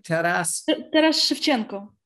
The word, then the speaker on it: Taras Shevchenko: Taras Shevchenko.